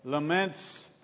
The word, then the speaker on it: lament: We are on week 8, so we are in the middle of the laments portion. A few people pointed out to me last week that lament is the correct pronunciation.